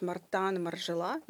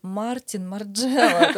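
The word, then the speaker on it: Martin Margiela: потому что мне так непривычно говорить Мартан, Маржела. Ну, я все прекрасно понимаю, дизайнер, э, там, э, происхождения определенного, что вот э, именно так нужно произносить его имя и фамилию.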